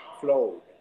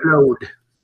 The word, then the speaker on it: Claude: He's the only person, and he did it correctly. Claude. He did it correctly, so you got Claude. Yes, Claude.